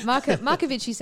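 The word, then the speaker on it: Marcovicci: At Italian pronunciation. – It's just a name. – Yeah. Yeah. Markovici sounds right.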